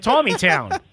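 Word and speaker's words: Taneytown: It's like Tawny Town. Okay. – They pronounce it Tawny. It's spelled like Taney.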